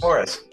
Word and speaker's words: Morris: Your surname, is it Morris or Maurice? – Morris, M-O-R-R-I-S, yeah, okay, Morris.